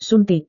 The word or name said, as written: suntik